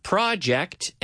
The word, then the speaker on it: project: But if you stress it over the second syllable, project, you mean, in other word, the verb. That's right. Correct. Yeah, so we have project and project, the verb.